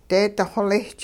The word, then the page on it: deedi xolisch: hupa